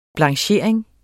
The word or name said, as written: blanchering